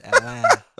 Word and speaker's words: Atlanta: Number three, we do not pronounce the second T in Atlanta. Deal with it. – It's Atlanta.